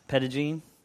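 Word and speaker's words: Petit Jean: We got to go to, I guess it's pronounced Petagene, last weekend, and we got to see it had rained.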